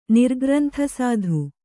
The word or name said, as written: ನಿರ್ಗ್ರಂಥಸಾಧು